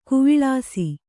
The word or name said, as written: ಗಾವಳಿಸು